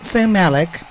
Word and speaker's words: Zayn Malik: Zayn Malik.